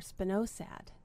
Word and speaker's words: Spinosad: It's called Spinosad or Spinosad. – Let's spell it. – It's S, capital S-P-I-N-O-S-A-D.